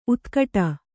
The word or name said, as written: ಉತ್ಕಟ